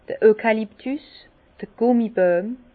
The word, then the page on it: d'r Eukaluptus, d'r Gummibojm: Alsatian; Bas-Rhin; Herrlisheim